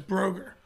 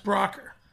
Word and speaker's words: Brocker: Yeah, I remember growing up, and my last name, you look at it, it looks like it says Broger, but it's pronounced Brocker.